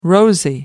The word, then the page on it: rosy: American English